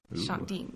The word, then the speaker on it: Shandiin: My username is at Shandeen. – How do you spell that? – S-H-A-N-D-I-I-N. It's my first name. It's like Cher. Shandeen.